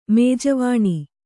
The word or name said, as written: ಮೇಜವಾಣಿ